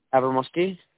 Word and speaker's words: Avramoski: Avramoski. – How do you spell that? – A-V-R. – A-V-R.